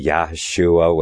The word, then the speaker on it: Yahshua: Now, I like to say Yahshua when I open the program, because I understand that's the proper pronunciation of his name.